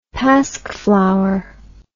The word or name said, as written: pasch flower